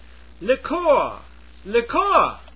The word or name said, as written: liqueur